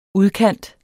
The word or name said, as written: udkant